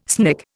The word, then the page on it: snick: Indian